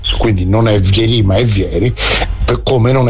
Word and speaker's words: Vieri: Peraltro, caro Cecca, anche il buon Francesco Benvenuti ci scrive che si pronuncia Vieri, quindi non so, oramai diciamo che mi fido molto, quindi...